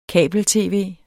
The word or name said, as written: kabel-tv